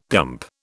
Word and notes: dump: British English

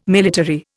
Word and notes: military: Indian

